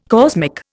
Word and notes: cosmic: Indian

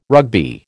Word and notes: Rugby: American English

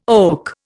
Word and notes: oak: Indian

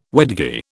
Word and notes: wedgie: British English

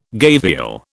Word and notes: gavial: British English

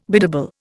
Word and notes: biddable: Indian

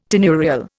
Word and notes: tenurial: Indian

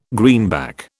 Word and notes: greenback: British English

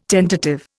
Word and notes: tentative: Indian

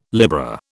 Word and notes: Libra: British English

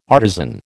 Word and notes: artisan: American English

